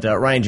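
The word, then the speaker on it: Rian: It's spelled Rain. I don't know. But Ryan Johnson, like, if you look at his body of work, like, he's a very interesting director and a very interesting writer.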